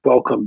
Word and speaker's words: Qualicum: I may have butchered that particular pronunciation, so if I have, please forgive me. – It's up the coast on Vancouver Island. Yeah, Qualicum Beach. Okay. You're close.